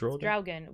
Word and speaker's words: Draugen: It's Draugen, which Draugen.